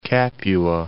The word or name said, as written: Capua